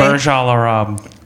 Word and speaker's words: Burj Al Arab: Then you got a 7-night stay in the top royal suite at the, I'm not going to pronounce in Dubai, Burj Al Arab.